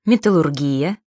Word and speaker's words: металлургия: Правильно произносить «металлургия», «индустрия», «терапия», но при этом «гастрономия», «кулинария», «логопедия».